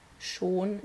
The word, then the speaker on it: schon: Um, it also means already, like Schon is how we pronounce it.